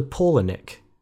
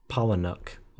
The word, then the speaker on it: Palahniuk: It's also Paulinik, not Palahniuk, or.